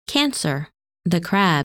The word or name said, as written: Cancer